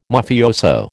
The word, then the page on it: Mafioso: American English